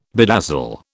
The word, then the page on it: bedazzle: British English